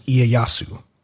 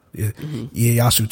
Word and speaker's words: Ieyasu: It's I-E-Y-A-S-U. – I can't. – And the way that I've always heard that name pronounced is Ieyasu. And they were pronouncing it like Ieyasu.